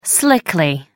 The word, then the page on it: slickly: American English